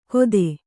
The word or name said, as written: ಕೊದೆ